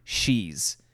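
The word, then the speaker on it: sidhe: shees. And that's spelled S IDHS, and that's where we get Banshee from.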